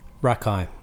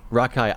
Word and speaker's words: Rakei: Rakei. – Rakei.